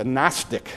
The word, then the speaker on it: Gnostic: And he wrote it to refute a philosophy that was in the world at that time called the Gnostic philosophy, G-N-O-S-T-I-C, the G is silent.